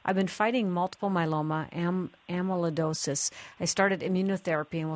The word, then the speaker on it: myeloma: Multiple myeloma, I think I'm saying that right. – Yep. Okay, mm-hmm. Yeah, that's exactly right.